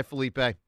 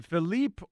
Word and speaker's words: Felipe: Hi, Felipe. – Hey, good morning, guys. – Hey, Felipe. Is it Philippe or Felipe? – It's Felipe, but Felipe. Lots of Americans say it Felipe.